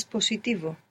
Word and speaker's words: positivo: Good, and you pronounce the S, where most English speakers will probably find themselves pronouncing a Z there, because in English we have positive, positive, so that would probably transfer into Spanish to positivo, and we want to be careful not to do that.